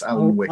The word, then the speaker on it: Alnwick: It is Anik, I think, even though it's not spelt that way. It's Alnwick.